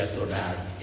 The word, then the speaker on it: Adonai: Però si legge Adonai, ecco, nessuno lo pronunciava Yoyah, e sono le vocali di Shema, il nome.